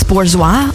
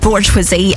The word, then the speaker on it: Bourgeois: But now we've got the Throttle House guys alongside Francis Bourgeois, which I think is how you pronounce his second name. I keep going to say Bourgeoisie, and that's definitely not it, but it's spelt like that.